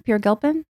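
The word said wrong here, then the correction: Peri Gilpin: Perry Gilpin. – Perry Gilpin, excuse me. Oh, I'm thinking of somebody else. – Spelled P-E-R-I.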